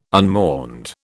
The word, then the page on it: unmourned: British English